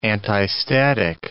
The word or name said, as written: antistatic